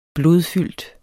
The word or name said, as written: blodfyldt